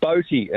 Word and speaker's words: Bothe: Yes, Boaty, and it's spelled B-O-T-H-E.